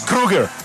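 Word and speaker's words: Kroeger: Vea que el apellido, el man que habló de cómo se pronunciaba el apellido y es como el de Freddy. Por más que se escriba con O. – ¿Sí? – Es Kruger.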